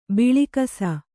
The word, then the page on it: ಭಾಳಾಕ್ಷ: Kannada